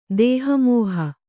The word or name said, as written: ದೇಹಮೋಹ